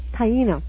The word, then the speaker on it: Taino: Taino.